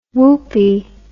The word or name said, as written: woopie